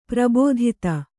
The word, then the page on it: ಪ್ರಬೋಧಿತ: prabōdhita